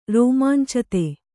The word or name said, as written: ರೋಮಾಂಚಿತ